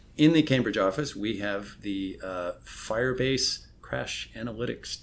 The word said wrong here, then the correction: Crashlytics: team. Oh, did I say that wrong? – All right. – I got nothing here. – Just okay. Crashlytics team. – Crashlytics. Oh, yeah. I see what you did. You kind of removed the indefinite article.